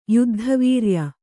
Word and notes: ಯುದ್ಧವೀರ್ಯ: kannada

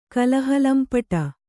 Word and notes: ಕಲಹಲಂಪಟ: kalahalampaṭa

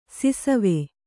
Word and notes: ಸಿಸವೆ: Kannada

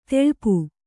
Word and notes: ತೆಳ್ಪು: teḷpu

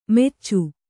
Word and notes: ಮೆಚ್ಚು: meccu

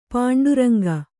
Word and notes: ಪಾಂಡುರಂಗ: pāṇḍu raŋga

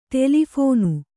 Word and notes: ಟೆಲಿಫೋನು: ṭeli phōnu

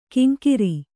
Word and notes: ಕಿಂಕಿರಿ: Kannada

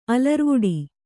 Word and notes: ಅಲರ್ವಡೆ: alarvaḍe

